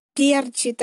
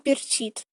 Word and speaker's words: перчит: И меня удивило очень ударение «перчит», а не «перчит».